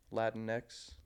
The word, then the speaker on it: Latinx: How the fuck do you pronounce that? – Latinx.